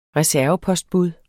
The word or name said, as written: reservepostbud